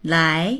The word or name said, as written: lai2